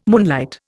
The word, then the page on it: moonlight: Indian